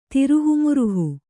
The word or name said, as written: ತಿರುಹುಮುರುಹು